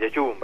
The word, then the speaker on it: Jeju: Bueno, él nos va a contar todo sobre el 5º Congreso Mundial de la Naturaleza que tendrá lugar en Jeju. No sé si se pronuncia así. – Sí, sí, en Jeju, es en Corea.